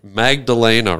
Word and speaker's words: Magdelena: Magdelena, right? – Yes.